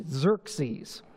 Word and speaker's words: Xerxes: It's an X. You pronounce it like it's a Z, Xerxes.